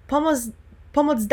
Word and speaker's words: POMOC: And it's spelled with a C at the end, so it can be a bit confusing because, you know, if we were to stick to English pronunciation, it should be POMOC. But the reason why we say POMOC is it's also a word in Polish, so spelled with a C, it means help.